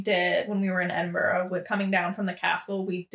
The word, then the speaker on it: Edinburgh: It's spelled like Edinburgh, like G-H at the end, but they say Edinburgh over there.